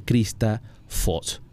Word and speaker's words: Krista Foss: El libro A la cara de Krista Foss.